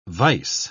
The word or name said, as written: vice